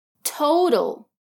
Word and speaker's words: total: It was a total. Watch out for the pronunciation of total. The two T's have different sounds.